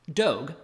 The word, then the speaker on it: Dogue: Dogue, which rhymes with Vogue, opened last month in the city's trendy Mission District.